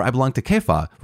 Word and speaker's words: Kepha: It's C-E-P-H-A-S, which is Cephas, right? But Kepha, I think, is the one acceptable pronunciation of the name of Peter, or I belong to Christ.